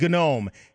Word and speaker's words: GNOME: Ubuntu uses an interface called GNOME, and yes, apparently they do pronounce the G.